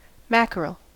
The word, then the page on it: mackerel: American English